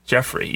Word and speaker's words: Geoffrey: It's just pronounced Jeffrey, even though it's spelled Geoffrey.